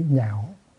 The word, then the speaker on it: nhạo: Chứ không có nói nguyện lạc dục văn. Nó là chữ lạc, nhưng mà đọc là chữ nhạo. Sau này sẽ có một chữ lạc khác, mà cũng phải sửa lại thành chữ nhạo.